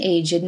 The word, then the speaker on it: aged: The following ED words used as adjectives are pronounced with id: aged, dogged, ragged, blessed, learned, wicked, crooked, naked, wretched. So we say, an aged man, a blessed nuisance, a dogged persistence, a learned professor, the professor who was truly learned, a wretched beggar, the beggar was wretched.